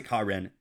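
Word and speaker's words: Ka'ren: Uh, I just got a kick out of realizing his name is K A apostrophe R E N. And I was like, they pronounce it Karen.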